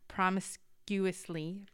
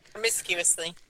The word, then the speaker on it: promiscuously: It says, I will not punish your daughters when they act promiscuously. Promiscuously? I'm not saying that right. – Promiscuously.